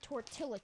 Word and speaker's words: tortilla: Flavored tortilla chips. – Correct. Tortilla. That's how you say it.